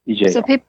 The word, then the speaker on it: Ejiao: Ejao? – Yeah, it's spelled Ejiao. Yes. Ejao.